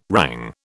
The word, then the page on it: rang: British English